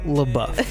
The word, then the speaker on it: LaBeouf: LaBeouf.